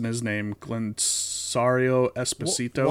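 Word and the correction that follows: Giancarlo Esposito: Juan Carlo Esposito?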